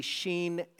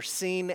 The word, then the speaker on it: shin: It looks like in English, sin and shin, but the real way to pronounce it would be sheen and, or seen and sheen, seen and sheen. And so I want to make sense of this for you as best I can without spending too much time here. The 21st letter in the Hebrew alphabet can be pronounced two ways, as seen or as sheen.